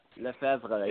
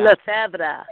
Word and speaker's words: Lefevre: How do you say that last name? – It is. LEFEVRE. LEFEVRE. Yeah. – LEFEVRE. – Exactly. LEFEVRE. Yes, sir.